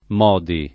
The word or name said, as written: Modi